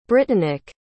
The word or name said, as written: brittanic